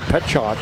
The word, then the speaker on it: Pietrzak: That's Cade Petchock. P-I, it's like pronouncing Cooper C's last name, but it's, you know, P-I-E-T-R-Z-A-K. And so so Petchock, a West Fargo Cheyenne kid.